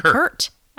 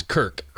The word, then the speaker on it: Kirk: Kirk Hammett. – Yeah, Kirk Hammett. – Oh, Kirk. I just called him Kurt. Okay. Well, yeah, it's Kirk.